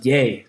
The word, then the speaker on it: Ye: Yeah, he changed his name to Yee West. – Facebook also to Yee or Yay? – Y-E. – Yeah, so Yay. He pronounces it Yay, I'm pretty sure.